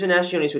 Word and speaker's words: venationes: So they also, another name for the beast hunts were venationes. Is that correct, Caroline? – I believe that that's how you pronounce it, yes. – Okay. Yeah, so these venationes would take place all throughout the empire.